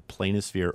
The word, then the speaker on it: planisphere: And it's, I want to spell it because it's kind of a weird word, P-L-A-N-I-S-P-H-E-R-E. And you can pronounce either planisphere or planisphere. It basically means a plane of a sphere.